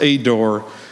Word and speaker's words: Eder: And it's spelled E-D-E-R, but I think it's pronounced Ador.